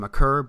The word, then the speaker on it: Maker: So you bring in guys like Jabari Parker and Thon Maker, who apparently it's pronounced McCurr, but I'm going to say Maker.